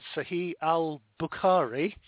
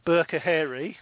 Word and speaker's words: al-Bukhari: It's superseded by the verse from, as Kelso rightly said, Sahih al-Bukhari, or Burkahari, as I heard you pronounce there, Kel.